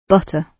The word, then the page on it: butter: British English